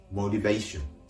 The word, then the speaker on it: motivation: もう一度発音します動機は英語でモリベーション。まあ、日本語でモチベーションなんてなってますね。